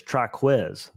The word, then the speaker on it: Triquez: It is Triquez. It's like Jacquez, but Triquez.